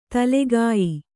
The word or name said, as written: ತಲೆಗಾಯಿ